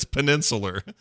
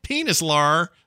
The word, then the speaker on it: peninsular: Oh, it's peninsular. – It's not penis at all. It's like peninsula.